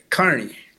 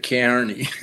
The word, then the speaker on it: Kearney: The people from Nebraska have reminded me of this as a town called Kearney, Nebraska. He was named after a Civil War general. And he pronounced it Kearney.